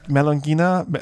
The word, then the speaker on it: melongena: How would you pronounce that? – Melongena. Solanum Melongena would be when I do like German Latin.